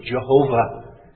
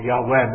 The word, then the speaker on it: Yahweh: The word Lord here is that wonderful covenant name, Jehovah, as we sing it in our psalters, or Yahweh, more accurately pronounced.